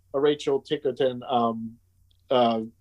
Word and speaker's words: Ticotin: Is uh, Paul Newman's partner, and then um, Rachel Ticotin, I, I, think is how you say her name from um, Total Recall. – Yeah, Ticotin.